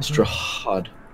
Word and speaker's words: Strahd: You gotta get that H pronounced. Mm-hmm. Strahd. – Yes. – You gotta when you say it. Yeah, you got the hocking. It's got to happen.